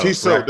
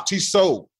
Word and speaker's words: Tissot: Tissot. Right? The T. Hey, man, don't you have any culture? The T is silent. – None. – The T on the end is silent. It's Tissot.